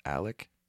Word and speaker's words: Alex: First of all, his name is spelled like Alex. – Yes. – And it's pronounced Alec.